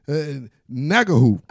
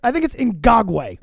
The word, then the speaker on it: Ngakoue: One more time, Yannick Ngakoue. – I think it's Ngagwe.